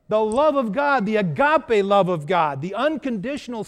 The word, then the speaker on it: agape: It's kind of confusing because if you see it in the English letters, it looks like agape, but it's pronounced agape.